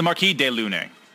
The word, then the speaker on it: Marquis de Lune: Are they the De La Lune or De Lune? Like, what are they? – I looked up how to actually pronounce it out. It's the Marquis de Lune.